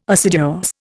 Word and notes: assiduous: Indian

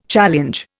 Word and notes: challenge: Indian

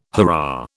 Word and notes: hurrah: British English

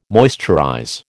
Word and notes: moisturize: American English

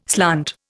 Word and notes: slant: Indian

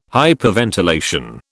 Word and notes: hyperventilation: British English

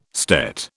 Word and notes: stet: British English

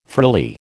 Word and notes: frilly: American English

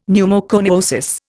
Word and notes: pneumoconiosis: Indian

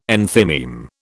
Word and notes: enthymeme: British English

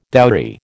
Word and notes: dowry: American English

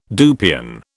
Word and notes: dupion: British English

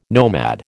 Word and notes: nomad: American English

